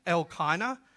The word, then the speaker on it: Elkanah: I've learnt during the week that it's Elkanah because you always put the emphasis on the L part, which is the God, because his name means God who's saved.